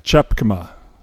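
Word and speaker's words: Tjepkema: Chepkema. – Chepkema. – Chepkema. – And it's spelled with a T and then a J.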